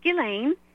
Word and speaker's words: Ghislaine: And it's all being shown to us right out in the open, you know, like this with Ghislaine, right? And I appreciate you pronouncing it properly, the French, right?